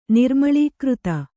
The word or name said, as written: ನಿರ್ಮಳೀಕೃತ